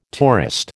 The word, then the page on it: tourist: American English